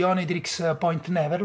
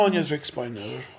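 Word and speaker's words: Oneohtrix Point Never: l'ho detto bene? Sì, so sì. che molta gente non... Cioè, ho letto, ma come cazzo si dice, come si chiama? – Come... Onitrix Point Never. – Onitrix, io lo leggo come... cioè lo pronuncio come si legge.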